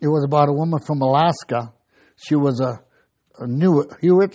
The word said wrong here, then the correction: Inuit: Inuit? – Yeah.